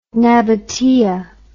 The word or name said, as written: nabataea